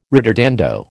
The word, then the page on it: ritardando: American English